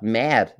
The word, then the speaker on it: mad: But mad, in Danish, the word mad, spelled the same way, M-A-D, means food.